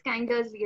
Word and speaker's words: Kindersley: He was a researcher at Darling Kindersley, and I hope I've pronounced it right. I think it's Kindersley, right? Yeah.